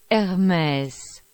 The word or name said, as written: Hermes